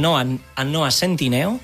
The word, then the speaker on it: Noah Centineo: El Noah Centineo, no sé si ho he pronunciat bé.